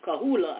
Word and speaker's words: Coahuila: The community of El Nacimiento, birthplace of blacks, is part of the municipality of Merquiz in the Mexican state of Cachuela, right under Cahuila, I know I pronounced that right, right under Texas.